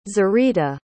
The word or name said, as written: zarita